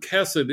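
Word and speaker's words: chesed (חסד): Yeah, it appears as an H-E-S-E-D, but with a dot under the H, which in Hebrew signals that you pronounce it as the C-H in Bach. Now, most Hebrew words, you don't need this, but it's worth knowing, most Hebrew words are pronounced with the emphasis on the final syllable. But in fact, the word chesed is one of the special words where you pronounce it with the accent on the first syllable. So it is chesed.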